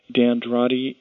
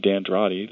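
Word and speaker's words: D'Andrade: They don't pronounce it Dandrati the way that I do.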